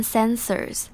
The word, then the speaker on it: sensor: sensors 就是感应器的意思那这边后面的 OR 是读成 R 的发音所以是 sensor